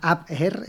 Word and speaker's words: Abwehr: La Abwehr, es que es muy difícil, es con W, es difícil de pronunciar, es en alemán.